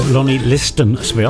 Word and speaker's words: Lonnie Liston Smith: wonderful Lonnie Linton Smith. Lonnie Liston Smith. I always say his name wrong.